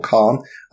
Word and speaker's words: Cairn: there's a role-playing game system called Cairn, or Karn. I'm not sure how to pronounce it. C-A-I-R-N. – Cairn, I think that would be. – Yeah. I would get that. – That's how I would say it, anyway.